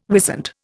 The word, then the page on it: wisent: Indian